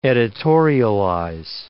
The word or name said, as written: editorialize